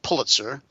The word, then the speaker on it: Pulitzer: I'm used to pronouncing that last word Pulitzer, but he pronounces it Pulitzer, multimillionaire businessman and inventor.